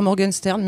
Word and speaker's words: Morgenstern: c'est Mes 18 exils de Suzy Morgenstern, je le prononcerai comme ça. – Oui, voilà, moi j'ai tendance à dire Morgenstern, mais Morgenstern c'est très bien.